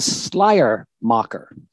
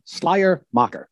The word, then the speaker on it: Schleiermacher: So let me teach you how to do it. Friedrich, so we say Friedrich, that's fine enough. But his name is Schleiermacher. Schleiermacher. Repeat that. Schleiermacher.